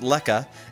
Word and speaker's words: lekker: All right, well, the word spelled lekker, pronounced lekka, is a widely used term indicating that something is great or nice. For example, the food was lekka, and that is South African slang.